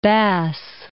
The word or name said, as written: Bass Strait